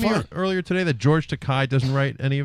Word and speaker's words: Takei: It's and, Takei. You know, it rhymes with sorry.